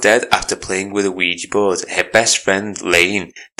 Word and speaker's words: Ouija: I'm really overthinking how you say it. Ouija, I say, and on the film they said Ouija.